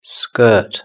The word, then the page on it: skirt: British English